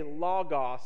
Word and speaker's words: logos: Don't call it logos. Logos. It is logos. Say it correctly.